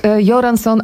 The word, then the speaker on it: Göransson: No i właśnie autor muzyki, czyli Ludwig, powiedziałaś Göransson. – Ja powiedziałam Göransson, bo jakoś z dawien dawna jestem przyzwyczajona do tego, że szwedzkie G na początku czyta się jak I.